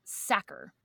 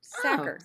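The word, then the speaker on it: Sachar: And I looked it up and it is Lewis, but the last name very unintuitively is pronounced Sacker. – Sacker.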